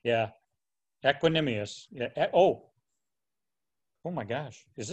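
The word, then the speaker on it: Equanimeous: Oh, no, you mean Equanimius. – Is that how you say it? – The Notre Dame guy? – Yeah. – Yeah, I think it's Equanimius St. Brown.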